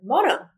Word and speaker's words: mono: He's from Puerto Rico, so I'm, i was like, babe, how do you say monkey in Spanish? She said, and he's like, mono.